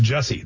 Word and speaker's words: Jussie: But this actor Jussie Smollett, and I've heard people call him Jesse, but I'm pretty sure it's Jussie. It's spelled with a U.